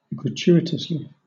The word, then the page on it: gratuitously: British English